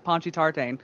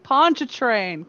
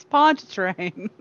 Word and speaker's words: Pontchartrain: and he pronounces it Ponchy Tartane. – Ponchy Tartane. Oh goodness. – It's Pontchartrain. Come on, guys, it's Pontchartrain. Ponchy Tartane.